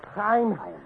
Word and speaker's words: Chaim: And so they turned to a young man by the name of Shane Wiseman. I ain't saying it right. How you say it? – Chaim. – Just like that.